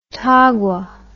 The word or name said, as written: tagua